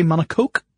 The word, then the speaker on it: monocoque: And the team entered the history books by being the first group to construct a car around a carbon fiber monocoque. That's how Scott pronounces it. I would say monocoque because I think of it as French.